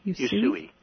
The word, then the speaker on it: Usui: Is that U-S-U-I? Is that Yusui? – Yusui.